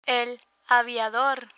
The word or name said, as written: aviador